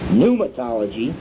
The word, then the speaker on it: Pneumatology: Pneumatology, that's spelled P-N-E-U-M-A-T-O-L-O-G-Y.